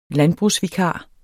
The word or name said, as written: landbrugsvikar